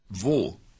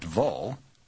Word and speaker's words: wo: Wo, not vo, as in vote.